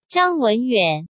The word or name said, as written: Zhang Wenyuan